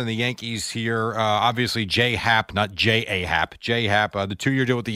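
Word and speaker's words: J.A. Happ: Right, but he wants it pronounced J-Hap.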